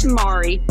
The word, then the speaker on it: Mari: Is it Mara or Mari? I don't want to mispronounce it. – It's Mari. Yeah.